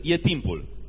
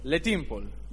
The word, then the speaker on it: e timpul: Și cele două cuvinte sunt e timpul. – And Colleen taught me how to say it's time in Romanian. – L-am învățat cum să spună e timpul în limba română. – Le timpul.